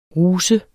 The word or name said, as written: RUSERNES